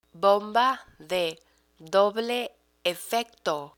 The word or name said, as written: bomba de doble efecto